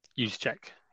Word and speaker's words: Juszczyk: Can't think of how you say his name. Your guy, Kyle Juszczyk. – Use check. Yeah, juice.